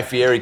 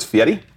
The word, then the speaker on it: Fieri: We just met Guy Fieri came up to us after the NBA. You mispronounced it. It's Fieri.